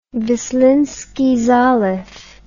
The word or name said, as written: Vislinsky Zaliv